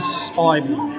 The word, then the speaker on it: Spivey: So my name's Rhys Spivey. – Spivey. I think I probably would have got it right, actually. – Yeah, it's as it's spelled. Spy V.